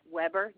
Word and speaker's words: Weber: It's Weber, W-E-B-E-R.